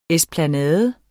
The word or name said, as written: ESPLANADEN